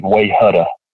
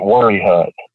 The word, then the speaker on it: Wayehutta: But at the same time, there are things like there's a place here that it's spelled W-A-Y-E-H-U-T-T-A, which to anybody else would look like Way Hutta. But we pronounce it Worry Hut.